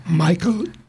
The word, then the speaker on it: Michael: Pero la siguiente canción es de un Michael Torres. Michael pronunciado en inglés y Torres en español.